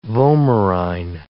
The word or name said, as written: vomerine